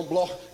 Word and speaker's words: Bloch: Se escreve Bloch, mas se pronuncia no alemão Bloch, judeus alemães.